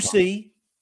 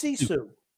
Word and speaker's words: sisu: It's not Susie. It's Sisu.